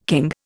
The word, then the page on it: king: Indian